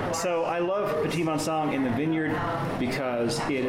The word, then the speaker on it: Petit Manseng: It's spelled Petit Manseng, but the French pronounce it something more like Petit Manseng, a very popular grape, an up-and-coming grape in Arizona, and I think it's great with great potential here in Arizona.